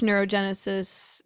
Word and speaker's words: neurogenesis: I imagine from a biological perspective, it's a bit of a delicate dance though too, because if there's too much neurogenesis, that could maybe lead to cancer.